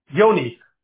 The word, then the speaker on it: Joigny: J-O-I-G-N-Y. – Okay. Yes. Literally read joined knee, but you can assume that is not the pronunciation. Okay. So let's call it Yoni.